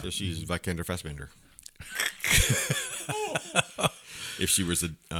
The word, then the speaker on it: Vikander: Alicia Vikander. – Easy for you to say. – Yeah. – Rhymes with Salamander.